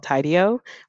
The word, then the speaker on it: Tidio: I think earlier I was talking to you guys about this like chat app and or plugin for Shopify, and it's called Tidio, which is spelled T-I-D-I-O, and I guess like kind of like tidy, but it keeps all of it's a pop up.